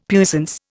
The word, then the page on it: puissance: Indian